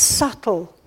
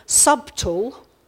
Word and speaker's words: subtle: Now, one of my daughters is an English teacher, and she would understand a lot about silent letters, but I don't know how many of you pronounce this word subtle. Oh, that was very subtle. It's sneaking into the middle of the word, but we never use the letter. What's it doing there? It's just there because English is difficult for spelling.